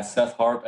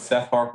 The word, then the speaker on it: Seth Harp: At Seth Harp Esquire is my Twitter handle. – S-E-T-H. H, two H's, A-R-P, so the way your name is spelled.